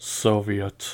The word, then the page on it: soviet: American English